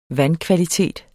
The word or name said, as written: vandkvalitet